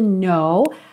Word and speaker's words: know: Repara que o K da palavra know é mudo.